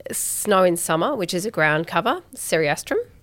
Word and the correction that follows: Cerastium: Cerastium tomentosum. – Cerastium, I always get that wrong.